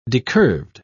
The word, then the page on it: decurved: British English